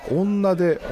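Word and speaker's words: 女手: ひらがなっていうのは当時は女で女の手と書いて女でって読む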